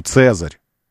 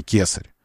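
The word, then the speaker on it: Caesar: К не было у них, С было. Поэтому дигитус имподицус. Ну, не было у них произношения на К, К, К. Было С, поэтому Цезарь, а не кесарь, Цезарь.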